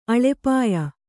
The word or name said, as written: ಅಲ್ಪಾಯು